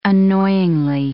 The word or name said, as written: annoyingly